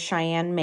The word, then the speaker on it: ShyAnne: My name on there is Cheyenne Maine. It's S-H-Y-A-N-N-E. My last name is spelled M-A-I-N.